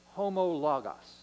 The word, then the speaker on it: homologos: If we confess our sins, the Greek word there is a compound word, and it's a word that's pronounced homologos. Now, homo actually means same.